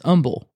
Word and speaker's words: humble: Sorry to let you in on the harsh truth so late, but it is not humble. It is humble. Please pronounce the H.